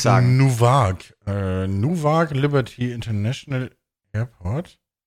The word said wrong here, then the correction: Newark: N-E-W-A-R-K. Newag. Newag? Newark? Newark? Newark? Ja, das halt.